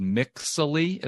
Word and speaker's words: Mixily: The one that I use, like, and recommend is called Mixily. It's spelled M-I-X-I-L-Y.